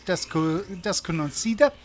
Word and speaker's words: Desconocida: Adios Desconies. Whoa, how do I say that? Desconocida. Desconocido. – Sure. – Adios Desconocida.